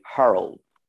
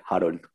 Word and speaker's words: Harold: Well, my name is Harold. And, well, like the Americans say, Harold. But I say it like Harold.